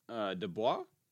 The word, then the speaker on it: DeBose: Nominees are Kirsten Dunst, Power of the Dog, Jesse Buckley, The Lost Daughter, Tessa Thompson, Passing, Ariana DeBose, did I say that right? – I think it's DeBose. – DeBose? Okay. – I think it is from what I've heard. About DeBose. – Yeah, there's no I, so it wouldn't be DeBose.